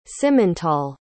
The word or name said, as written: simmental